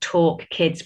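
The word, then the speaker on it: Tauk Kids: And there's also a new, new YouTube channel, which I'm part of as well, which is called Talk Kids, but it's spelled T A U K Kids.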